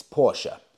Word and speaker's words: Porsche: And it's true, I've been pronouncing Porsche wrong my whole life. It is in fact Porsche. Even Porsche confirm this.